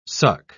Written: suck

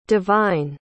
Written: devine

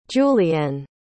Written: julianne

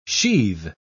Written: sheathe

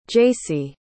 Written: jacey

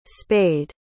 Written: spaid

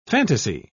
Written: fantasy

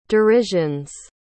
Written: derisions

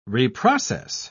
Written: reprocess

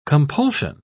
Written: compulsion

